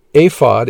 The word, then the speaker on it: ephod: It consisted of the following items: ephod, the ephod pronounced ephod in Hebrew, was an article of sacred clothing worn by the high priests of the Levitical priesthood.